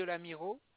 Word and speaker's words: Lamirault: Deux rue de la Miro. – Deux rue de l'Amiro, comme ça se prononce? – L'Amiro, ça s'écrit LAMIRAULT.